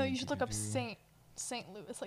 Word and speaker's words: Louis: It's pronounced Louis. – Like the city is? – L-O-U-I-S. Well, this says the S is silent, but I think it's just an American just, pronunciation or an English pronunciation.